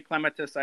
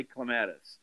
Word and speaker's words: clematis: And she told me, she goes, there is no official pronunciation. So you say clematis, I say clematis.